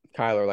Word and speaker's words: Kyler: Kyler, like Kyler Murray.